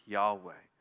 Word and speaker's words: Yahweh: The divine name is most likely pronounced something like Yahweh.